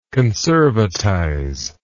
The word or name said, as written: conservatization